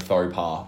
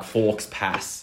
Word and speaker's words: faux pas: But the thing was, he actually nailed the pronunciation of faux pas. – What is faux pas? – I was hoping you would say like forks pass.